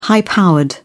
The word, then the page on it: high powered: British English